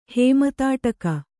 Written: ಹೇಮತಾಟಕ

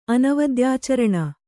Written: ಅನವದ್ಯಾಚರಣ

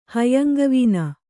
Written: ಹೈಯಂಗವೀನ

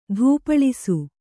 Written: ಧೂಪಳಿಸು

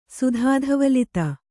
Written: ಸುಧಾಧವಲಿತ

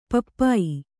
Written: ಪಪ್ಪಾಯ

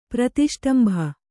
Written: ಪ್ರತಿಷ್ಟಂಭ

